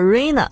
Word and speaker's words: Rina: 当时呢，刘若英和金城武一起给陈升担任过 3年半的助理，而刘若英的英文名字呢叫做 Rina，读起来的发音结尾呢就类似于奶。